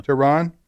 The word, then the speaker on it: Tehran: And I remember somebody telling me, a friend of mine said he flew into Tehran. Is that how you pronounce Tehran. – It? – Yeah. – Tehran.